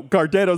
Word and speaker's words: Gardettos: And she said at first she said Gardettos. No, Gardettos. Like she even mispronounced it and then corrected herself out loud.